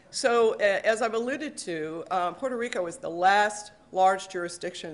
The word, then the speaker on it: Puerto Rico: Puerto Rico as well. So just so you know, I can say it properly.